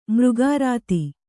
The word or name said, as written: ಮರುಗೆರ್ದೆ